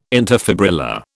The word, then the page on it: interfibrillar: British English